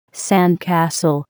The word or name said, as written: sandcastle